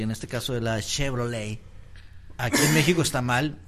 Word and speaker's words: Chevrolet: Que viene de un apellido francés. – De un apellido. – Apellido francés. ¿Cómo se dice? – Chevrolet. – O sea, la Blazer Chevrolet. – No se Chevrolet. – Dice en la última letra. – Chevrolet. – El Chevrolete.